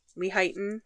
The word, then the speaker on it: Lehighton: It's located at 635 Seneca Road in Lehighton, Pennsylvania. – Lehighton. – It looks like it would be Leighton, but... they all pronounce it Lehighton, so we'll go with that.